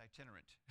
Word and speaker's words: itinerant: In 1739, Franklin said, arrived among us from Ireland the Reverend Mr. Whitfield, who had made himself remarkable there as an internet preacher. It's not internet, it's itinerant.